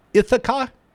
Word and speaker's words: Ithaca: And gentlemen, you're going to have to tell me if I'm pronouncing the name of the title of the movie right. Ithaca? – Yeah, that's it.